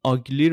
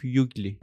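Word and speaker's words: ugly: این آگلی رو میگفت یوگلی یوگلی تلفظ میکرد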